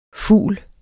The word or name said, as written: ful